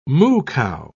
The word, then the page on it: moo-cow: British English